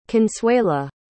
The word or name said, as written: consuela